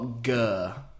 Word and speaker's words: Gurr: The act are Gurr. – Gurr, lovely. – I believe that's how you pronounce it. G-U-R-R. – I call them Gurr. – I can't roll my R's.